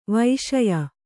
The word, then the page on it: ವೈಷಯ: kannada